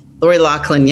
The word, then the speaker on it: Loughlin: Lori Loughlin, yes.